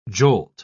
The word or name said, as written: jolt